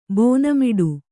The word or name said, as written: ಬೋನಮಿಡು